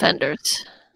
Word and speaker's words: Wenders: would you pronounce the W as a V? – Yes, he's a German director, so yeah. It's Wim Wenders.